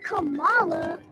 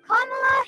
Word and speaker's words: Kamala: It's not Kamala. It's Kamala Harris. Kamala's for our schools. – Kamala's for affordable child care.